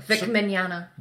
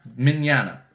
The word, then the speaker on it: Mignogna: I can never pronounce that. – Vic Mignana. – Mignana. Mm. It's the G placement.